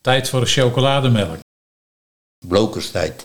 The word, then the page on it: Blookerstijd: Nieuw lekkerlands